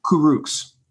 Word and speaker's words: Kurucs: Kurucs.